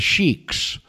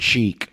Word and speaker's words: Sikh: It's Sikh, not Sheikh. Sheikh. it's with an S and not a C.